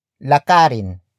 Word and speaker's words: lakarin: The first word is lakarin, which is spelled as L A K A R I N.